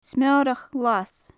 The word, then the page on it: smeorach ghlas: gaelic